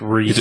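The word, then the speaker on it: Rhys: It's like R-H-Y-S. I think it's from It's pronounced Reese.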